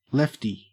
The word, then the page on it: lefty: Australian English